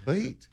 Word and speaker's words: Cleet: Cleet. Cleet on the electric guitar. Cleet. Bartholomew on the drums. He's a drummer, obviously a drummer. – Yeah, it's that one syllable like flea.